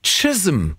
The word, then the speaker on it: Chisholm: Melanie Jane Chisholm. So wird es ausgesprochen. Das ist aber wirklich ein C, was da geschrieben steht und wird mit T ausgesprochen. Jetzt wissen wir Bescheid.